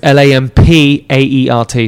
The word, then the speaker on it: Lampaert: So that's like how I phonetically pronounce it, but it's L A M P A E R T.